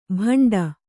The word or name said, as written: ಭಂಡ